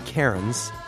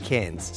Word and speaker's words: Cairns: And I remember on a trip to Australia, I went to Cairns, but I would have called it Cairns because that's how it's spelled. But that's how everybody says it. Even the Americans there are saying, I'm on my way to Cairns.